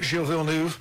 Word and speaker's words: Gilles Villeneuve: Gilles Villeneuve.